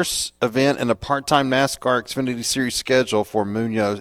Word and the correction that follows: Muniz: Is it Munoz? Is that how you pronounce it, Chris? – I think it's Muniz. – Muniz? Okay, M-U-N-I-Z, but I did not know that.